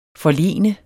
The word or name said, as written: forlene